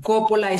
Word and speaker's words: Coppola: Jacqueline es J-A-C-Q-U-E-L-I-N-E, e, Jacqueline. – Ok, se los voy a poner en la descripción. – Ajá, Coppola es C-O-P-O-L-A.